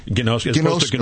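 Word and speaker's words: Ginosko (γινώσκω): Ginosko. As opposed to... – It's a hard gi. – Ginosko. Gnosis. – Yeah, the gi, like, and it comes into English as a K, K-N-O-W, or knowledge.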